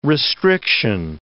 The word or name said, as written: restriction